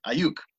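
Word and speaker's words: Aiyuk: I'm going to butcher his name right here. Brandon Ayuk. – Brandon Ayuk. – Ayuk. There we go.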